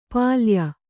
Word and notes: ಪಲ್ಯ: palya

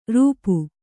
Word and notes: ರೂಪು: rūpu

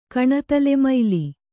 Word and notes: ಕಣತಲೆಮೈಲಿ: kaṇatalemaili; Kannada

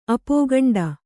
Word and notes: ಅಪೋಗಂಡ: apōgaṇḍa

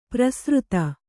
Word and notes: ಪ್ರಸೃತ: prasřta

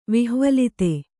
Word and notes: ವಿಹ್ವಲಿತ: vihvalita

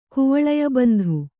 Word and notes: ಕುವಳಯಬಂಧು: Kannada

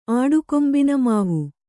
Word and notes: ಆಡುಕೊಂಬಿನಮಾವು: kannada

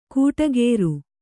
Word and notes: ಕೂಟಗೇರು: kūṭagēru

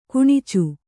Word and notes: ಕಾಣಾಚಿ: kāṇāci

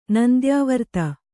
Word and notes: ನಂದ್ಯಾವರ್ತ: nandyāvarta; kannada

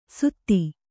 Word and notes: ಸುಧಿ: Kannada

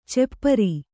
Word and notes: ಚಪ್ಪಿರಿ: cappiri

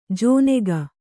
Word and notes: ಜೋನೆಗ: Kannada

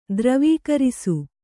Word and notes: ದ್ರವೀಕರಿಸು: Kannada

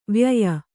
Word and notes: ವ್ಯಯ: vyaya

